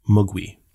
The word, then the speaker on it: Mogwai: So in Cantonese, Mogwai means devil or demon or gremlin. And the Mandarin pronunciation is Mogwi.